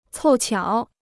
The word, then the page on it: 凑巧: còu qiǎo